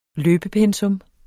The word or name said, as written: løbepensum